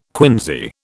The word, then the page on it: quinsy: British English